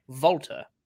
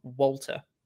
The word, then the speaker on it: Walter: And as a native German speaker, he pronounced it correctly if it had been the case, like Volta rather than Walter.